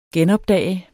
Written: genopdage

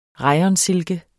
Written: rayonsilke

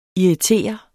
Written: irritere

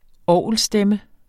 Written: orgelstemme

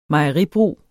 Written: mejeribrug

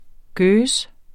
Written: geuze

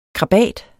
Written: KRABATERNE

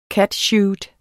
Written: catsuit

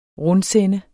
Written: RUNDSEND

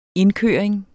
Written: indkøring

